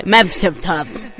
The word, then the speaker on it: Medvedev: Eh, eh, più o meno, io ho detto Medvedev, lei no, non, sì, hai detto giusto, tutto. più o meno, si dice Medvedev.